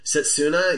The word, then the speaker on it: Setsuna: Am I saying that correctly? – Setsuna, yeah.